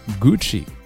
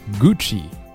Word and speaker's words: Gucci: 巴黎世家 Balenciaga Balenciaga 当然你也可以按照西班牙语的方式去读 Valenciaga Cartier Cartier Cartier 赛琳我们前面说过 Celine Celine 香奈儿 Chanel Chanel 迪奥 Christian Dior Christian Dior 下面是非常常见的牌子 Gucci 古驰 Gucci